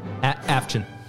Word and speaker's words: Avchen: Avchen. – A-V-C-H-E-N.